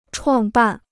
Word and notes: 创办: chuàng bàn

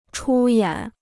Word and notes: 出演: chū yǎn

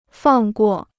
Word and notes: 放过: fàng guò

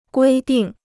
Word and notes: 规定: guī dìng

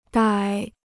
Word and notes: 逮: dài

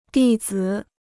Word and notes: 弟子: dì zǐ

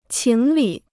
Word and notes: 情理: qíng lǐ

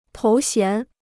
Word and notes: 头衔: tóu xián

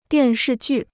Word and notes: 电视剧: diàn shì jù

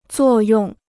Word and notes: 作用: zuò yòng

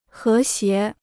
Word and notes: 和谐: hé xié

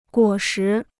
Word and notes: 果实: guǒ shí

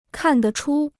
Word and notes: 看得出: kàn de chū

